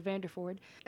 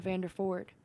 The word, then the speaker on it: Vanderford: And, spelled the same, Laura and everything, except for the end of Vanderford. And I've had people say, oh, like, at school. Or church or something like that. people have mispronounced my name as Vandervoort instead of Vanderford, because I guess they just thought Vandervoort.